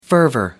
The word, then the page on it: fervour: American English